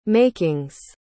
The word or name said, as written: makings